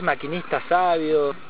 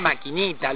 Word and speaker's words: Maquinista Savio: Hasta decían Maquinita, lo pronunciaba mal.